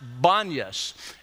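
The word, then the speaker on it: Banias: or in Civil Creek we'd say Banyas, but they say Banyas. Banyas. It's pronounced Banyas,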